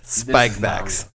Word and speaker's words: Spikevax: Spike Vax.